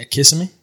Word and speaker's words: Kissimmee: We made it in Kissimmee, Florida. – How do you say it? – Yeah, Kissimmee. – That's how I say it.